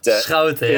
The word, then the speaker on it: Schouten: He's allowing, I don't know how you pronounced Jody Schouten's surname before, was it Schouten? – But Schouten, yeah.